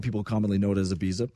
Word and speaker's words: Ibiza: With European accent, by the way. – Right, yeah. – You have to say Ibiza.